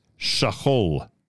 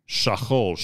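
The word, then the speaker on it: shachol (שכול): Pronunciation of today's word is shachol, shachol, shin chaf lamed, mishpacha shkula.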